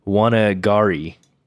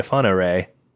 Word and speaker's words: Whangarei: It's Whangarei, which doesn't rhyme very well either. I'm like, no. Halfway to Wanagari? What? That doesn't really work. So I had to look up the pronunciation just to be sure. And so Halfway to Afanare works so much better.